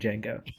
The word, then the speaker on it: Django: Yeah, I figured that was a joke, but then I realized there was another character named Django. – Well, this one is with a D. The D is silent.